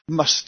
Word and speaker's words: Mast: But he could, and they were called Mast, M-A-S-T, must they pronounce it must.